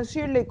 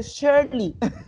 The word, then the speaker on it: Shirley: pero ya estoy tan acostumbrada a que me digan Shirley como se, como se escribe, pero realmente se dice Shirley.